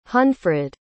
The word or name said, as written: hunfrid